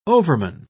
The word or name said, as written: overman